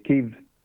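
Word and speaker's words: Kyiv: And now that they, obviously, we're learning of this now because Ukraine being obviously in the news, but it's Kiev is the pronunciation.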